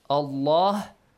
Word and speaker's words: Allah: Allah nun.